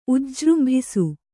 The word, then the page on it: ಉಜ್ಜೃಂಭಿಸು: kannada